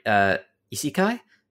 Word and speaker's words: isekai: I don't want to say a letdown, but it was a little bit, I think I have the pronunciation right. Isekai. That's it.